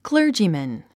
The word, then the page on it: clergyman: klə́ːrdʒimən; クレジィマン